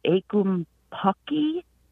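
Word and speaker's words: ackumpucky: And as I was trying to find this word and find something, I came across a word, I think you would pronounce it akumpucky.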